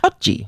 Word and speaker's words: potjie: but it's known as a potje. And essentially it looks like a cauldron. So can you spell that for me? – P-O-T-J-I-E. – Potje. – Potje. Okay.